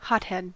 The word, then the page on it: hothead: American English